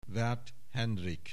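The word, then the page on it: Werth Hendrik: Hungarian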